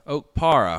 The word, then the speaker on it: Okpara: Felix Okpara, I hope I said that right. – That's correct. – Had 10 Okpara.